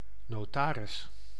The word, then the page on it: notaire: [ɛ̃ nɔ.tɛʁ]; /nɔ.tɛʁ/